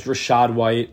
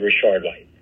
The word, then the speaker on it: Rashad White: it was Rashad White, and then Tyler Allgeier on the Falcons with Cordarrelle Patterson out. – If you heard it, he pronounced his name wrong, so it's actually Rashad White.